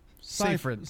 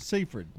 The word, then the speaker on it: Seyfried: Amanda Seyfried. – Seyfried, okay.